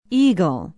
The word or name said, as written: eagle